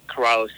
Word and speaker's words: Ciraulo: Yeah, you guys can follow me at Cole Corallo Racing on Instagram. And then I have a Facebook too. – And Corallo is spelled... – Cole... C-O-L-E, Corallo, C-I-R-A-U-L-O, and I use Instagram mostly.